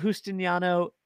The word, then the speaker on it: Justiniano: Tiana Justiniano, thank you so much.